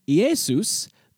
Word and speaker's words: Iesus: In the name Iesus, the initial I is followed by the letter E. In Latin, the letter E is pronounced A, again, similar to its descendant in the modern Romance languages. When you pronounce the E and A sounds quickly side by side, they kind of slur into one sound.